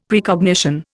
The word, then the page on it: Precognition: Indian